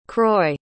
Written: croix

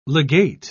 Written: legate

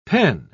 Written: pen

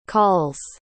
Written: calls

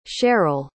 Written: sharell

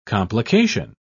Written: complication

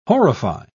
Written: horrify

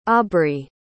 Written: aubry

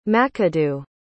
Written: mcadoo

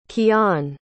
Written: kiaan

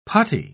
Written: puttee

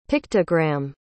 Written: pictogram

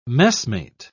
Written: messmate

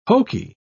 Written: hokey

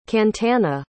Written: Kantana

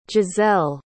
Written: jazelle

